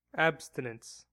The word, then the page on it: abstinence: American English